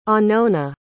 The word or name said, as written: anona